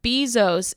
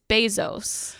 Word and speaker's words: Bezos: I think you said Jeff Bezos. It's Bezos.